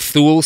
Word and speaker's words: Thule: It's spelled Thule Society, like the top racks that people have on their four-wheel drive cars. T-H-U-L-E.